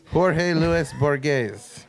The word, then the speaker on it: Jorge Luis Borges: Who is George Louis Borges? Borges? Am I saying it right? Or Borges? – I don't know. This was also, I believe, the season I started including pronunciations. – Jorge Luis Borges.